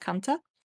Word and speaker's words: Kantha: And it's spelled K-A-N-T-H-A. Mostly I've seen it pronounced Kantha in like English sources, but I believe it's actually pronounced Kanta.